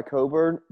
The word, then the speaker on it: Cockburn: Well, it's spelled Cockburn, but he goes by Coburn for obvious reasons.